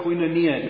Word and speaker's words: koinonia: The Greek word for fellowship, as our Greek scholars in the room will know, is the word koinonia, or koinonia, depending on how you want to pronounce it.